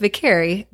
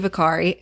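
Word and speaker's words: Viccari: So it's Vicari, but it could also be Vicari. And I've defaulted at times to saying Vicari because people can spell that. Like, it seems more natural. They understand it's V-I-C-C-A-R-I. But if I say Vicari, then people want to add two R's, or they want a Y, or I don't know why.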